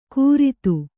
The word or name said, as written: ಕೂರಿತು